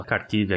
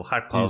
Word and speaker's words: Kharkiv: Só pra avisar o ouvinte que às vezes ele tá ouvindo a Kharkiv aqui e tal, às vezes na mídia sai com a pronúncia russa, né? Que é, é o Harkov.